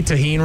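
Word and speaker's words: tahini: Did I say tahine right this time? Because last time I tried to pronounce it, people got mad at me. I said Thai gin.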